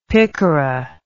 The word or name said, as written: picara